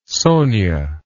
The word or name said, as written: Sonia